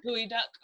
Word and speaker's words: geoduck: Gooey duck. Mm-hmm. It's spelled like geoduck, but it's a gooey duck.